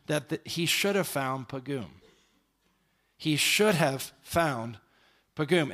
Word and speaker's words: paggim: Now it's spelled P-A-G-G-I-M, but it's pronounced in Hebrew pagum.